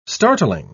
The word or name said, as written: startling